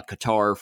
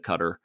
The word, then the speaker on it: Qatar: It's been held in Qatar before, uh, Qatar for those who don't properly pronounce the way that country should be said, Qatar.